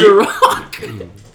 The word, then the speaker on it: Ciroc: That's not how it's pronounced, David. – How is it pronounced? – Ciroc. – Oh, It's great. Ciroc.